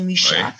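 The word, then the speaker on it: Mucha: Et là, j'aimerais bien euh, rappeler le nom Alphonse Micha, ça se prononce euh, en français Micha, en tchèque, euh, qui est sûrement connu aussi pour les Français, pour tous les Français.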